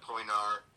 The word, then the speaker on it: Poenari: If you look up Poinar, it's spelled P-O-E-N-A-R-I.